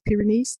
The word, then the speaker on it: Pyrenees: in the Pyrenees, is it pronounced Pyrenees? – Yes.